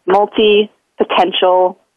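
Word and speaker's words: multipotentialite: One of the things that can help to remember how to say it is to just break it up into three parts. So just multi-potentialite.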